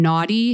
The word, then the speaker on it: Noughty: The reason I heard about you was through this brand, Naughty Hair Care. – Yeah. – Is it pronounced Naughty or Naughty? – It's spelled N-O-U-G-H-T-Y. – And the British say Naughty.